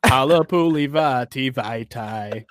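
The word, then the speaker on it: Halapoulivaati Vaitai: You sing it to play that funky music, White Boy. Halapuli Vaati Vaitai. You'll never say it wrong again.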